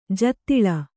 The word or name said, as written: ಜೂದಾಳಿ